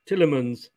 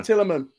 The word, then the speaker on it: Tillemans: Talisman? I never know how to pronounce it. – Tillemans. – That's the one, Tillemans.